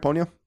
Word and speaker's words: Ponyo: While Ponyo is, am I saying that right, Ponyo? – Yep.